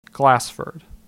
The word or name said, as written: Glasford